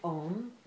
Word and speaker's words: om: So Swamiji says the word om is made of the three syllables a, u, m. A and um. A and u make for a diphthong, o, right? So that a and u is o, and then with the m it seals it, om.